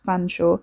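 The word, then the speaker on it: Featherstonehaugh: The senior Mr. Chalmondley, pronounced Chumley, of course, in the way that Featherstone Howe was pronounced Fanshawe, had never failed in this respect, although as far as Wes could tell, he'd failed in almost every other.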